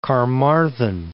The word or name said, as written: Carmarthen